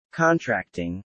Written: contracting

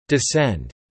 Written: descend

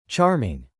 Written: charming

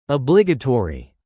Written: obligatory

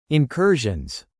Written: incursions

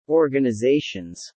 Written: organizations

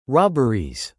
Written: robberies

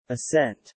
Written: ascent

